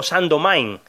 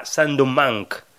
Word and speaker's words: Saint-Domingue: Venía a decir algo así como Saint-Domingue varias veces, y eso principalmente es porque la documentación que usé y algún podcast que escuché de la BBC hablando del tema, pues utilizaba esa pronunciación, Saint-Domingue, cuando habría que ponerla en francés un poco más y decir algo parecido a Saint-Domingue.